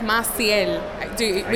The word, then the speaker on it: Maciel: So my name is Maciel Betances. – I'm glad you said that, not me. – It's Maciel. We can do it phonetically.